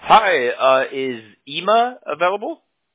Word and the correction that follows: Emma: Is it Ema or Emma? – No, it's just Emma. – Okay, okay. So, Emma with Local Splash.